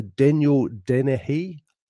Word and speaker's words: Daniel Deniehy: Is it the Daniel Deniehy Oration? – Yep, that's right.